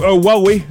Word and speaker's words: Huawei: Allons-y avec UI, ou Huawei,